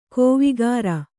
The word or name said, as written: ಕೋವಿಗಾರ